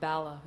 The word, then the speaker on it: Bala: And so they said, well, you know, we'll put you in the only dry place in all of Bala, is the name of the town, which rhymes with Dala.